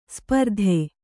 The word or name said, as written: ಸ್ಪರ್ಧಿ